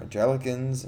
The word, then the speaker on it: Anglican: Like you have your Catholics and your Baptists and your Anglicans and your Angelicans. – I don't know, how do you pronounce it? Did I pronounce it wrong? – Anglican? – Anglican? – I think that's what it is.